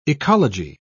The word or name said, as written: ecology